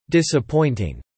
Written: disappointing

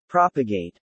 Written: propagate

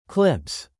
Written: clips

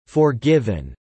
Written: forgiven

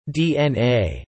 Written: DNA

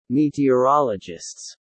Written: meteorologists

